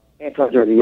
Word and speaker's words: Antongiorgi: Antongiorgi, yes.